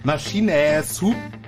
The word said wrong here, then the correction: Machine Supremacy: Du kannst es doch bestimmt besser, aussprechen. – Machine Supremacy heißt es.